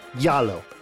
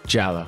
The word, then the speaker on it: Giallo: Is it pronounced Giallo or Yallo? – Giallo. – Just like yellow. Yeah, giallo, giallo, giallo. Is the G silent? – No. – No, no. it's soft.